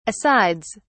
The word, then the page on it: asides: British English